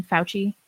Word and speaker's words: Fauci: Fauci, is that how you say his name? Fauci? – Yeah.